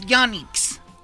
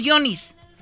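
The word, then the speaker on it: Yonic's: Miren, son tanacos que además ni siquiera saben pronunciarlo bien. Son los Yonix. – ¿Cómo? ¿Cómo? – Les dicen los Yonis. Los Yonis.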